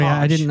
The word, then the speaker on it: Hauppauge: It's Hupapauge. H-A-U-P-P-A-U-G-E. Hupapauge. – Hupapauge. – Hupage. Yeah, sorry, Hupage.